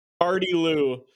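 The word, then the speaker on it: gardyloo: All right, let's actually find out what the real word is. It'd be funny if we had the wrong pronunciation. Gardy Lou.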